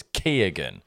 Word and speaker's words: Keoghan: But I've read recently the G is pronounced. – Yeah. – Therefore it's Keoghan.